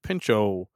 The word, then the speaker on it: Pinchot: Yeah, the Gifford, the T would have to be silent, right? – That's what I'm thinking, too. – The T is silent, so it would be the Gifford Pinchot, National Forest.